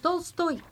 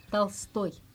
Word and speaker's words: Tolstoy: Vous dites Tolstoy, mais non, Tolstoy. – Voilà, c'est parce que l'accent est sur toy. – Oui, et donc c'est O, si ce n'est pas accent tonique, donc c'est O un petit peu comme A. – Voilà, là, c'est différent. – Okay. Si l'accent ne tombe pas sur le oui. O, le O se transforme en A, Tolstoy.